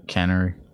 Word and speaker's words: Cannery: And I parked over there by the Canopy Ballroom. – Yeah. – Did I say that right? – Cannery. – Yeah. The Cannery Ballroom.